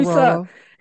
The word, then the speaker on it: saguaro: said so I said saguaro cactus, and he's like, Saguaro.